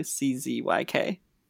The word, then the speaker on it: Szewczyk: And you can find me just Jesse Szewczyk on all socials. Have fun spelling my last name. – Can you spell it for us, Jesse? – Oh, God. Szewczyk.